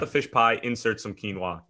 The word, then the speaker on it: quinoa: So his main course apparently is it quinoa? Quinoa. That's how you pronounce it, isn't it? Yeah. Not quinoa, as I used to call it.